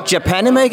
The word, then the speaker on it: Japanime: So, uh, joining me now is Eric from Japanime Games. Is that correctly pronounced? – Yes, that is correct.